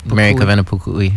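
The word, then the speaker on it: Mary Kawena Pukui: Mary Kavena Pukuli, yeah.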